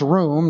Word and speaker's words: rum: The pelel imperfect of the verb room, which looks like this, R-U-M, and is pronounced room, not rum.